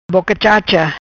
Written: Boca Chacha

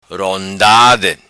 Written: Rondade

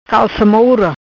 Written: Calca Moura

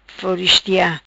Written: Floristia

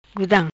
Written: Gudam

